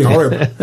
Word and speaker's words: Horeb: Remember the law of my servant Moses, the statutes and rules that I commanded him at Horeb for all Israel. Is that how you pronounce that? – Horeb? Sounds good to me, Horeb.